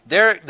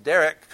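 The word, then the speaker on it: Derek (דרך): Derek, that's the way it's pronounced, Derek, comes from the Hebrew word darak.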